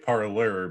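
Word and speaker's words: Parler: When I first heard parlor, I thought it was like a gambling term, but that's like parlay, isn't it? – It's spelled like parlay, but yeah. It's pronounced like parlor because freedom prize.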